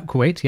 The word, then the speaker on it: Kuwait: That's how you say it? Kuwait? – Yep, that's how I would say it. Yeah, Kuwait, yep.